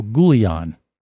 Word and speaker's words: Goulian: And also, Michael Goulian. I think I'm saying his name right. Is that it, Dennis? – Yeah, that sounds right.